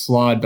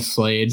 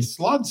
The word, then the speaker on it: Slaad: I think it's slod, but I've been saying slade. – Slod sounds right.